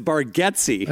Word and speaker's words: Bargatze: And Nate's like, "Yeah, but you've always said my name wrong, buddy." So it's Bargatze.